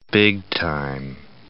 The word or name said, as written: big-time